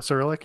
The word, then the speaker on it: Cyrillic: did I pronounce that right? Cyrillic? – Yeah, Cyrillic, yeah.